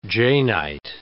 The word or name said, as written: Janeite